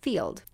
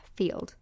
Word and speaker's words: Feeld: And then the other one is called Field. And it's spelled F-E-E-L-D, Field.